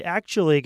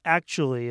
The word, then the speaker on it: actually: So sometimes when we say the word actually, it sounds like it only has three syllables. One, two, three. Actually. So that's how we often say it. But the real word has four syllables.